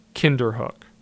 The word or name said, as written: Kinderhook